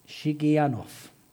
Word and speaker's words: Shigionoth: A prayer of Habakkuk the prophet on Shigionoth. And that's a correct pronunciation.